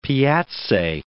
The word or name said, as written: piazze